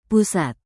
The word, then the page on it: pusat: indonesian